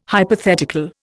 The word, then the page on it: hypothetical: Indian